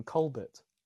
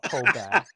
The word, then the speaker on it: Colbert: And uh, and also, he doesn't pr- pronounce his own surname properly. El- everybody knows that's called he's called Stephen Colbert, right? Not Colbert.